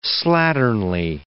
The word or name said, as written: slatternly